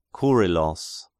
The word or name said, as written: Kyrillos